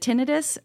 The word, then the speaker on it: tinnitus: It was like now I had space to see things differently and you still had to heal and, you know, uh, how do you pronounce the ear thing? Is it tinnitus or I tinnitus? Would, yeah, it's tinnitus. tinnitus. It's tinnitus. Either one works.